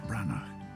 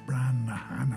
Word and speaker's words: Branagh: And Kenneth Branagh, not, as I've heard many of you pronounce it, Kenneth Branaghan.